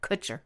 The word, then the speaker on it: Kutcher: Actors who started as models include Kellan Lutz and Robert Pattinson of Twilight fame, Josh Duhamel, Ashton Kutcher, rhymes with butcher, so we were both wrong in that episode where we were saying, is it Kutcher or Kutcher? It's Kutcher, because I looked it up.